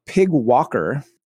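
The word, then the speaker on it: PyGWalker: PYG walker, and you might be saying, Michael, come on, pig walkers. This is, I know. It says right here, pronounced pig walker, just because you can and it's fun.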